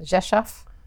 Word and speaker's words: Rzeszów: Rzeszów? – Yeah, very good.